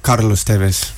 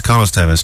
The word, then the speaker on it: Carlos Tevez: How do you say that name properly, first of all? – Carlos Tevez. – Great, Carlos Tevez.